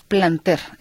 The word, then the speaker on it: Planter: Ella misma lo dijo, es Planter. Efectivamente, así como usted lo ha escuchado, Carlos, con acento en la E.